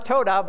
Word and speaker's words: todah: Now, it's pronounced todah, but to me it always sounds like ta-da because of its definition.